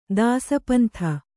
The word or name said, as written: ದಾಸಪಂಥ